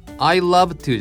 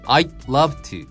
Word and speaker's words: I'd love to: これはね、ちょっと細かいところなんだけれども、この I'd love って書いてあるんだけど、この D の処理の仕方ね、これね、実は発音しないんだよね。で、小さいつみたいに溜める。 I love to じゃなくて I'd love to。